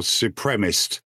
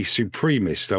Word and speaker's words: supremacist: I'm afraid I'm more upset about the way in which that woman pronounced supremacist or supremist. The word is supreme, I believe, and someone who believes that someone is more than somebody else is would be a supremacist, a white supremacist.